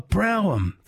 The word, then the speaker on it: Brougham: A Brougham. Thank you, Pete. And a few other people have given me the right pronunciation for that magnificent Australian car from the early 70s that I did not notice or see on my streets.